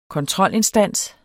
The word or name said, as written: kontrolinstans